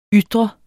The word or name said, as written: ytre